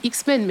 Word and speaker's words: X-Man: En fait, on dit X-Men, mais en vrai, les gars, ça s'écrit X-Man, car en créole, enfin, pas toi jamaïcain.